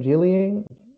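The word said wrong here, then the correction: Jing Liang (景亮): Mm-hmm. I can't say it right. – Jing Liang.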